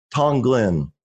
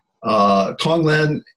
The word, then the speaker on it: Tonglen: Now, you also talk about a practice of Tonglen.